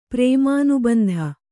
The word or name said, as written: ಪ್ರೇಮಾನುಬಂಧ